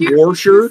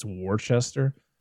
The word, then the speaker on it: Worcester: But if you look at the word, it's Worcester. – Yes. – Yeah. – And I'm like, where are the rest of the letters in this word? – Just don't pronounce them.